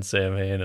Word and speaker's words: Samhain: It was Glenn Danzig was in the Misfits, and then Samhain, and then Danzig. So, fun fact, on everything it says the pronunciation of the word was Samhain. But it looks like Samhain, so I don't know.